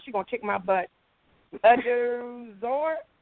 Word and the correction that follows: Udeozor: it's Udeozor. That's all.